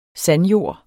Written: SANDJORD

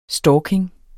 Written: stalking

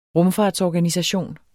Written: rumfartsorganisation

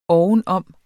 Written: ovenom

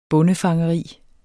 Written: bondefangeri

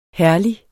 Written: herlig

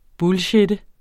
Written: bullshitte

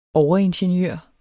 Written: overingeniør

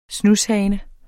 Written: SNUSHANES

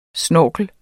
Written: snorkel

